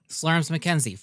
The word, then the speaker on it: Slurms McKenzie: It's like Slurmid McKenzie. – Slurms McKenzie. – Is it? – Slurms McKenzie from Futurama.